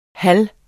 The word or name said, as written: halv